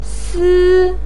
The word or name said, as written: si1